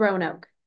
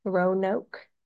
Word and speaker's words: Roanoke: I like the, I don't know how to say this. Renoki. – I think it's Roanoke. – Roanoke.